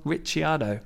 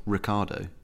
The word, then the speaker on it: Ricciardo: And I actually checked the Italian pronunciation of his name because, you know, people like Christian Horner have been calling him Ricciardo, and I checked it, and yes, it should be Daniel Ricciardo.